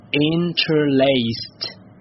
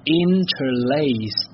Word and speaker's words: interlaced: 那么要注意的是呢，这个词呢加上 d 之后呢，它是念作，来跟我一起念，它念作 interlaced， interlaced，而不是 interlaced。我之前呢也一直认为应该是念作 interlaced 这样的吧，可是呢我在准备的时候呢一直查了一下，查了好几个字典呢，发现都是念作 interlaced，所以呢我确定应该是以这个 t 结尾。